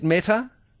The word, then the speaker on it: Meta: Am I pronouncing it right? Is it Meta? – Yes.